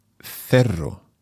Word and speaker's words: cerro: And it's C-E-R-R-O. So in Spain Spanish, we're pronouncing that as cerro. But if you're more used to Latin American Spanish, then of course you would hear el Cerro de Santa Lucía.